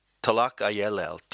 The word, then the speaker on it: Tlacaelel: Now, this myth is almost single-handedly the creation of one psychotic individual whose name is very difficult to pronounce, Tlacaelel. Tlacaelel, T-L-A-C-A-E-L-E-L, was the advisor to four emperors, including Moctezuma.